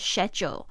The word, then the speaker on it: schedule: 这边的 schedule, 它的发音是 schedule, 这个 schedule 其实是英式发音哦在美式发音比较有可能会听到 schedule, 但是英式的口音都是讲 schedule, 好像水饺水饺 Alright, the very challenging part was to figure out how to get better.